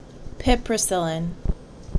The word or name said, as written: Piperacillin